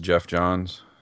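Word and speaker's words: Geoff Johns: Jeff, G-E-O-F-F, right? – Yeah, I believe it's pronounced Jeff Johns.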